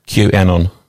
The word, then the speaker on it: QAnon: QAnon.